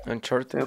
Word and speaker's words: Uncharted: Uncharted. Uncharted. – Fue la pronunciación. – Es, exactamente, al menos lo que han sacado, uff, yo creo que que va a ser algo demasiado, demasiado bueno.